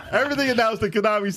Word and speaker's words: Konami: Konami. – I know that's how we say it, but people be saying it different. – Konami.